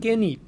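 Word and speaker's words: Genip (깻잎): There's a thing called genip that I like the most.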